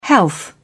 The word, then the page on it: health: British English